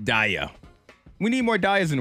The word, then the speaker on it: Daya: That was Don't Let Me Down, Chainsmokers featuring, I guess it's Daya, D-A-Y-A. That's how you pronounce her name.